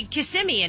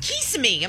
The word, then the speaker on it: Kissimmee: She's the one who's confused me, and I don't know how to say Kissimmee anymore. Yeah. I think, did I just say it right? – You said it right. – Yeah, because she's like, Kissimmee. I'm like, that's not how you say it.